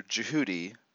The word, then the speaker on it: Djehuty: I want to just talk a little bit about the channel perspective from Ascended Master Jehudi, which is spelled DJEHUTY. That's his Egyptian or Kemetic name.